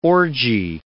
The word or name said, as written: orgy